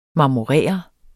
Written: marmorere

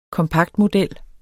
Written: kompaktmodel

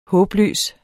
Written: håbløs

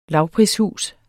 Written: lavprishus

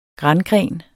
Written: grangren

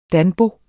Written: danbo

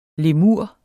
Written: lemur

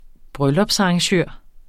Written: bryllupsarrangør

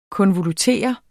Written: KONVOLUTERE

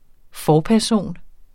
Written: forperson

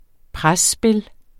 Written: presspil

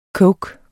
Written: coke

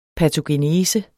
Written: patogenese

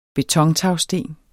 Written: betontagsten

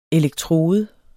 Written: ELEKTRODEN

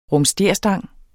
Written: rumsterstang

